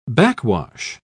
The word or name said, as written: backwash